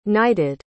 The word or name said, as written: knighted